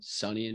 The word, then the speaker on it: Sonny: Do you know Sony and Cher, the singers? – Sony? No, I don't. – Sonny and Cher. Sorry, not Sony. Mm-hmm. It's just spelled like Sony.